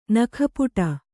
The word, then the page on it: ನಗುಪಾಡು: nagu pāḍu